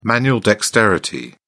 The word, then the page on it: manual dexterity: British English